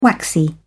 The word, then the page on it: waxy: British English